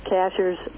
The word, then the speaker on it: Cashiers: Cashiers is the way they pronounce it around here, the name of the town. It's spelled just like a cashier at the grocery store.